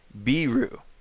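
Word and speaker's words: biru (ビール): You can also request some beer if you're thirsty for that drink by asking for biru. Biru is how to pronounce the American or English imported word for beer.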